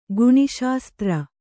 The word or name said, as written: ಗುಣಿಶಾಸ್ತ್ರ